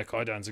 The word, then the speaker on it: Kwaidan: You're right, and they also put out um Kwaidan. Is that how you say it? – Oh yeah, Kwaidan's incredible. Kwaidan.